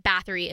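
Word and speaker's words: Báthory: And then Bathory is the correct way to say it.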